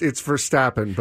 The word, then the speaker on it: Verstappen: How do you say his last name? – It's Verstappen, but you are definitely...